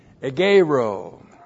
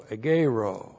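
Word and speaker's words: egeirō: Agero. E-G-E-I-R, long O, agero. Just a couple of things on this verb agero.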